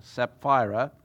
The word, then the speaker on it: Sapphira: But a man named Ananias with his wife Sapphira, by the way, you'll notice there are two P's there, so you pronounce it Sapphira, sold a piece of property.